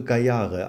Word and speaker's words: Gayare: Nee, also Gayare einfach. Es ist ein indischer Name und den haben wir eingedeutscht.